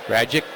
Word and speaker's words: Radjic: That's how he tells everybody to pronounce his name correctly, by the way. He'll say just it rhymes with magic. Radjic, magic.